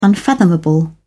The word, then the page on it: unfathomable: British English